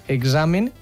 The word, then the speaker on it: examen: Jo crec que lligat a l'educació hi ha tres paraules com a clau que s'han de pronunciar igual, que seria la d'exercici, examen i exemple. – Uf, sí. – Que les tres van ahí lligadetes sí. I que s'han de pronunciar com una geseta, no?